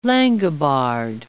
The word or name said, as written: Langobard